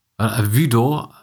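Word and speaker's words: Hvidovre: Is it called? Vudo? You write it Vudovre. Vudo, I think it's the Danish pronunciation.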